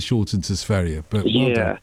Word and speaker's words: Sverige: No, Sverige. Sverige is the right, correct pronunciation. Well done, you, Ruby. Yeah, it's Sverige.